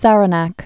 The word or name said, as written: Saranac Lakes